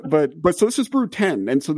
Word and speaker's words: Brood X: So this is, the famous one is not going to be pronounced Brood X. It's going to be pronounced Brood 10, isn't it? – Yeah, the Roman numerals.